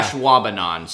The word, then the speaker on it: Ashwaubenon: Ashwaubenon. So it's said like it's spelled.